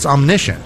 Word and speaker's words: omniscient: So another quality of God, the Father, and I mentioned it, I said it wrongly before, but it's omniscient, mm-hmm.